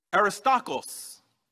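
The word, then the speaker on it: Aristarchos (Ἀρίσταρχος): I will try to pronounce them in the Greek as they would have been. Tukikos. Onesimos. Aristarchos.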